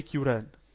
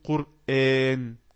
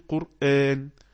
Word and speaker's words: قرآن: تمام، ان انا لما بقى في ناس بتقرأ تقولك ذا هولي كيوران. هولي كيوران، كيوران ليه؟ عشان هو شايف ان في بعض غير المسلمين لما ينطق يقولك كيوران. طب ما انا اعلمه يقول قرآن. تمام، اعلمه يقول قرآن. القرآن ده اسم علم على الكتاب اللي ربنا انزله. خلاص، فلما يقول ايه؟ يقول قرآن، ما يقولش كيوران.